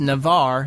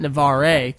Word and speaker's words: Navarre: It's Navar. It's not Navarre.